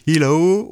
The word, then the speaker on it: eelo: Alors on sait pas comment on dit, on dit hello ou hello. – Hello, euh, les deux me plaisent bien. – Voilà, alors ça s'écrit E-E-L-O.